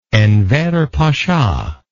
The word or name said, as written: enver pasha